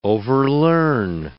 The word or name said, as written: overlearn